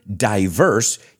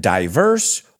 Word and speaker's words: diverse: And the word diverse, you can pronounce it diverse or diverse. Both are okay.